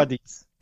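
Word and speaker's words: Cádiz: Das ist das Duell zwischen Cadiz und Sevilla. Ist das richtig ausgesprochen? – Ah, ja. Cadiz. – Cadiz? – Ja, besser. Cadiz.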